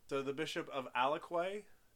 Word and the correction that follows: Aquila: is that? – Aquila. – Aquila. Is that how you say it? Aquila. The Bishop of Alaquila. – Aquila. – Has put a curse... – There you go.